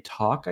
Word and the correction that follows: Tolk: I guess. – Called her Tolk. – Tolk.